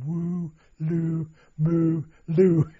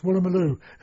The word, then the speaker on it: Woolloomooloo: I was going, woo, loo, moo, loo. And the Aussie said, it's Woolloomooloo.